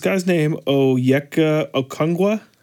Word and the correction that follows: Okongwu: Oh, Okongwu. For the Hawks.